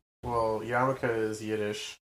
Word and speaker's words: yarmulke: We can always use Yiddish, and then it's yarmulke. – Yarmulk? – Yarmulk. It's kippah in Hebrew. – Yeah. Excellent. – I'll stress on that second to last syllable.